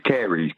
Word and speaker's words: caries: The word is caries, C-A-I. – No. Nope. Can I pronounce it for you? Yes, sorry. It is caries, caries. Oh, yes, caries.